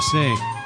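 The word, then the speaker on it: Sá: Yeah, that was Wanda Sa, or Wanda Say. Yeah, Wanda Say. S-A. You make you make up your mind which way to pronounce it.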